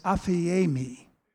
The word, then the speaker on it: aphiemi: Uh, the Greek word for forgiveness is aphiemi.